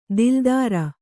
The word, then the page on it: ದಿಲ್ದಾರ: kannada